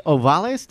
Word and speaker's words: Ovalles: But I want to introduce Andres Ovales.